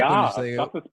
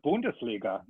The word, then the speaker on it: Bundesliga: We are this weekend, we're getting back Bundesliga. Am I saying that right? The German soccer league. – Yes. – We're getting back Bundesliga. – Bundesliga.